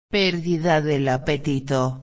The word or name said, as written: perdida del apetito